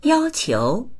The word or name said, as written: yao1qiu2